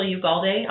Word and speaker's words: Ugalde: You can find me at Rachel Ugalde.